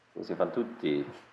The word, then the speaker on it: Così fan tutte: In addition to making films, this director wrote several books of poetry, has had photographs exhibited, and directed a production of Mozart's Così fan tutte. I don't know if I'm saying that right either. – Così fan tutti. – Tutti. Ah, thank you.